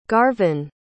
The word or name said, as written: garvyn